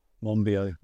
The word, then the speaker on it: Monbiot: Monbiot.